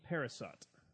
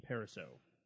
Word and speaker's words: Parisot: Is being directed by Dean Parisot, or Dean Pariseau, depends on how you want to pronounce that.